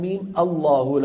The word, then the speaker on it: الم: وقيل أنه هي منقولة من ألف لام ميم الله، لو بديت بالله بتبدأ بإيه؟ بهمزة مفتوحة، فكأنهم نقلوا هذه الفتحة من لفظ الجلالة إلى الميم، فهي تنطق هكذا ألف لام ميم الله ميم الله.